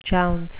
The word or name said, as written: jounce